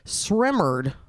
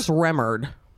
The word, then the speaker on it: Sremmurd: 2016 Black Beatles by Ray Sremmerd. – What? – Not kidding. – Please spell that last name for me. – S-R-E-M-M-U-R-D. – Sremmerd? – Sremmerd.